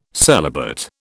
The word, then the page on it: celibate: British English